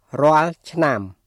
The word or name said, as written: រាល់ឆ្នាំ